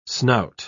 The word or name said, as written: snout